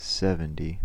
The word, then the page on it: seventy: American English